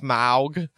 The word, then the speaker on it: Smaug: Yeah, because I think that's how it's like officially pronounced, Smaug. – Smaug. – That's the southern way. – Smaug. Smaug. You're so big. Oh, like, my God. – Did you just say smog? Like the desolation of smog? – I'd say smog.